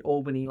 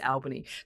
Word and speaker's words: Albany: Common mispronunciation is to make it Albany, like in the US, but yeah, it's Albany.